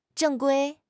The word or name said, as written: 正规